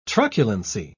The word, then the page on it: truculency: British English